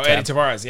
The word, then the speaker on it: Edy Tavares: Uh, oh, Edy Tavares.